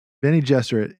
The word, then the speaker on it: Bene Gesserit: And so there's this great line in Dune where Jessica had quoted a Bene Gesserit proverb to him, if I'm saying that right. – Bene Gesserit.